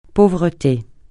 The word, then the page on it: pauvreté: francais